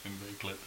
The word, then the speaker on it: inveigled: There's an L in there. – Inveigled. – How would you say that? – I would pronounce that inveigled.